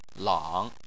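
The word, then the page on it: lang: tone 3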